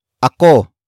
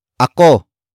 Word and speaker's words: ako: Up next we have ako. Ako. Ako. Ako is spelled as A-K-O.